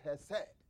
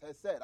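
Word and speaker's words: chesed: The Hebrews pronounce it hesed. It's hesed.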